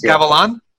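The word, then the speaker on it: Gavilán: But he is the second Paraguayan to join United, and uh, Diego Gal- uh, Gavilan. Am I saying that correctly? I'll get to your introduction soon. is it yes, Gavilan? – Gavilan.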